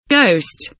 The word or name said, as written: ghost